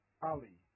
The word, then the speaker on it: Ali: Harry Ali. – Yep, spell it for me. – H-H-A-R-R-Y, Harry. – Yep. – Ali. A-L-I.